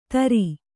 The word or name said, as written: ತರ